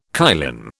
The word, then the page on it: kylin: British English